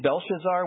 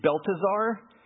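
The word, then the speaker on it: Belteshazzar: Daniel's name, I've been pronouncing it Belshazzar with his given name, but it's actually Beltazar.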